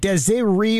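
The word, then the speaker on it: Des'ree: We were all thinking, okay, how do you pronounce her name? Is it Desiree? Is it Desiree? Is it Desiree? Well, what is it? It's Desiree.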